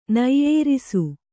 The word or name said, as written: ನೆಯ್ಯೇರಿಸು